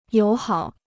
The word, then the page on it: 友好: yǒu hǎo